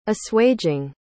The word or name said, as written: assuaging